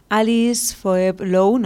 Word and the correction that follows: Alice Phoebe Lou: no sé si lo estoy pronunciando bien, tampoco sé si lo estoy pronunciando del todo correctamente. Creo que es eh, Phoebe, Alice Phoebe Lou.